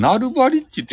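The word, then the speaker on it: Nulbarich: え、チャ、チャラ、チャ、ナルバリッチってどこにおるとえ、ナルバリッチってどれよそんなんおったナルバリッチって。えナルバリッチとかおらんよ。あ、これか。ヌルバリ、あ、これナルバリッチって読むね。え DJ、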